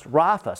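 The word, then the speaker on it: Rapha (רפא): The word heal in Hebrew is pronounced Rapha.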